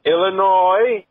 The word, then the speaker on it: Illinois: As a resident of the city of Chicago in the state of Illinois, you don't pronounce the fucking S at the end of Illinois.